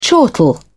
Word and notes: chortle: British English

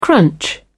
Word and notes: crunch: British English

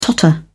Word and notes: totter: British English

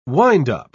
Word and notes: windup: British English

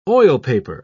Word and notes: oilpaper: British English

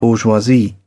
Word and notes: bourgeoisie: British English

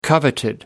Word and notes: coveted: British English

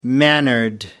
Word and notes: mannered: American English

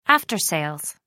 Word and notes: after sales: American English

